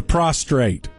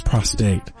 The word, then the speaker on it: prostate: They don't have the prostrate. As my grandmother always mispronounced it. – Correct. – Yeah, he had prostate problems. It's prostate.